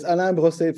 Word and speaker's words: Alain Brossé: So it's Alain Brossé,